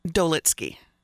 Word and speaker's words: Dolitsky: Dolitsky. – I knew I was going to pronounce it wrong, so that's why I made you do it. – Just like it's spelled.